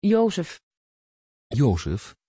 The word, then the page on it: jozef: Dutch